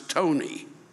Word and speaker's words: Ptoughneigh: P-T-O-U-G-H-N-E-I-G-H. And then in parentheses, pronounce Tony.